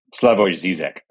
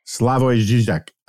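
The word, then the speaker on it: Slavoj Žižek: But you know, what's the Serb philosopher guy, Zizek? Is that how you pronounce it? – Slavoj Zizek. – Slavoj Zizek.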